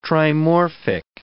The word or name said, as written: trimorphic